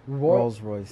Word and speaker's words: Rolls Royce: Royal Royce. I can't say it. How do you say it? – I know what you mean. – Yeah. How do you say it again? – Rolls Royce. – Yes, that.